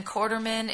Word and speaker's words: Quarterman: C-H-A-B-L-I-S. It's my first name. And then Quarterman is like quarter and man, 25 cents a man. So it's Q-U-A-R-T-E-R-M-A-N.